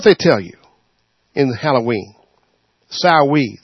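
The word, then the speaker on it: Samhain: It's spelled S-A-M-Sam-H-A-I-N. Because it's Celtic, it's pronounced Sawee.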